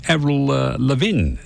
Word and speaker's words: Avril Lavigne: A lady called Avril Lavigne, that's how you pronounce it.